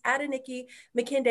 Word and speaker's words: Adenike Makinde: I will actually pronounce the whole thing. It's Adenike Makinde. It's actually Nigerian in origin.